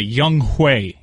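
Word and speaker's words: Younghoe: By the way, Young Hui is how you pronounce that.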